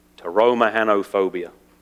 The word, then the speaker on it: Pteromahanophobia: Pteromahanophobia. Any clues? It's a little misleading because it's got a silent P in the front of it.